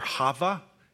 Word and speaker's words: Chava: In the Hebrew, it's, you might have even heard this before, Chava. Chava is the spelled with a K-H, the guttural thing, the Arabic sound, Chava.